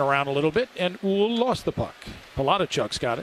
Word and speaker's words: Houle: Eventually taken by Huell for Lindenwood. – Oh, that's what I was going to say. I got a text. – Yeah. – H-O-U-L-E is a French name. The H is silent. – Oh, Huell. – Huell. – Well, according to the Lindenwood notes, it's Huell. H-U-L-E is how it's pronounced.